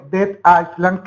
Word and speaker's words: Dead Island: Y lo segundo es hacer público mi hype, porque todavía tengo los pelos parados de ver el pedazo de tráiler que fue Dead Island, que me diga el maestro Relaño si lo estoy pronunciando bien.